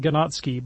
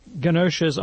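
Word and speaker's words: Ganoczy: Alexandre Ganoche, it looks like Ganotsky, but I think it's pronounced Ganoche.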